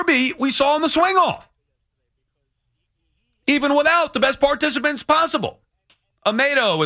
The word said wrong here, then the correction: Amadeo: is calling from, or how do you say your name? Amadeo. There you go. Amadeo from Staten Island.